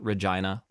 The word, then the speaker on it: Regina: It's pronounced Regina, like vagina.